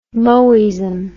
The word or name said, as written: moism